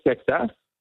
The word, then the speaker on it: Seixas: It is spelled S-E-I-X-A-S. We all had to go directly to Paul to check the pronunciation of his name because we didn't want to get that one. – No. – It is Paul Seixas.